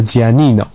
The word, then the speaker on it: Giannino: 哦，我们的店里面其实刚才美嘉有提到，我说我的店名叫某某某，其实他说错了，我的店名应该叫做发音应该叫 Giannino，不过中文名称应该叫乔安里诺，不过因为真的蛮绕口的。